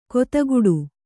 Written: ಕುದಿಗುಟ್ಟು

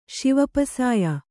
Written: ಶಿವಪಸಾಯ

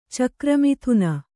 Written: ಚಕ್ರಮಿಥುನ

